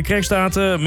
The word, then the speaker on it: Kerkstraten: De festiviteiten beginnen om 10 uur bij de Kerkstraten met een... over Kerkstraten, hoe spreek je dat uit? Jij weet dat beter dan ik. – Kerkstraten? – Ja, precies.